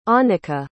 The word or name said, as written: annica